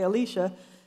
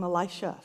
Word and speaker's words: Elisha: Some people have taken the second pronunciation of Elisha and say Elisha, and I tried that, but I've my whole life called him Elisha, so I will try to be clear as we delve into this scripture.